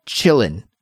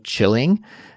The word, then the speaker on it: chillin': If we're using it in kind of a present continuous sentence, like I am chilling, that sounds really strange. So instead of pronouncing it that way, the more slang hip way to say this is just I'm chillin', I'm chillin', or chillin'. Okay.